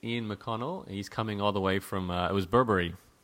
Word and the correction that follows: Bunbury: Burberry, am I saying that right? – Bunbury.